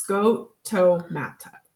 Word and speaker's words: scotomata: scotomata. I just feel like I'm not saying that right. – Schematia? – It's scotomata. – Scotomata.